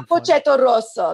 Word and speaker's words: Cappuccetto Rosso: That's so fucking Cappuccetto Rosso. That's how you say it.